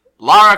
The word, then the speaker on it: Lara: Today, we're talking about Liara Croft nope. – And the Lara Croft and the Guardian of Light.